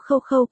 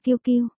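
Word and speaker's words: QQ: Cái gì mà khâu khâu, cái này đọc là kiêu kiêu. Một, vô văn hóa, ba cô ở bên cạnh chỉnh họng. Một, kiêu kiêu, mẹ Diệp Phàm đã đọc sai kiêu kiêu thành khâu khâu.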